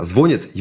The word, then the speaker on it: звонит: Хотя на самом деле язык сам меняет в сторону «звонит» происходит. Это и нормальная, естественно, форма, и это логично. Потому что во всех остальных словах, типа солит, варит и так далее, раньше ударение было в конец слога, а сейчас варит, солит и так далее. И поэтому все слова этого типа сдвинулись, а звонит его искусственно держат, потому что у нас ну, вот, эта вся языковая тема, поправлять вот это все, она еще связана с очень другой интересной особенностью.